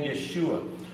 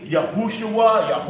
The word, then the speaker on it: Yeshua: And that's the name Yeshua. Some people say Yahushua, and that is incorrect.